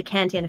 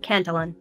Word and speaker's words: Cantillon: Cantillon effect, a French word that is sometimes pronounced in English as Cantillon.